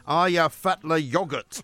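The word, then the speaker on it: Eyjafjallajökull: Aya Fatla Yogurt. Apparently, that's how you say it in Icelandic. Anyway, it doesn't spell like that, of course. It looks like Aya Fjallil Jokul.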